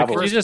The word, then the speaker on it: Babel: Yeah, Babel. could you just set the record straight and tell us how to pronounce it? – Yeah, it's Babel. I didn't think there was going to be like so much controversy over how the name's pronounced, but why is it Babel?